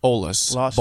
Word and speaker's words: Olas: Los Olas Boulevard. – Olas? Okay. – Like O L A S. Not there's no H, no silent H.